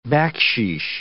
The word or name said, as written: baksheesh